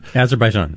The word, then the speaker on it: Azerbaijan: Armenia, Azerbaijan, how do you say that word? – Azerbaijan. – Thank you. Azerbaijan.